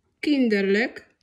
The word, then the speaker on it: Kinderlijk: Pamiętajmy, że L i J K na końcu wyrazu wymawiamy jako lyk. I takie słówka mamy. Frolik. – Frolik. – Aanvankelijk. – Aanvankelijk. – Kinderlijk.